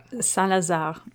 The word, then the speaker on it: Saint-Lazare: Saint-Lazare is how they say it.